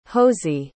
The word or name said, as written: hosie